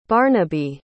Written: barnaby

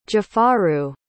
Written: jafaru

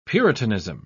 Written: puritanism

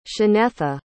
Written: shanetha

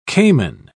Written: caiman

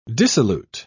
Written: dissolute

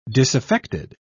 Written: disaffected

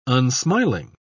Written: unsmiling

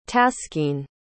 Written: taskeen

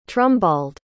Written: trumbald

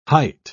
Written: hight

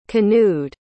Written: canoed

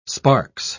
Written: sparks

